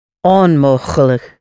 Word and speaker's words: onmogelijk: onmogelijk. – Onmogelijk. Look. L-I-J-K at the end of words is always pronounced look.